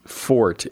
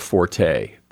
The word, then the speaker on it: forte: In, like, when you're talking about someone's strength or ability, it's again spelled F-O-R-T-E, and technically it should be pronounced fort. It comes from the French. It should be pronounced fort, not forte.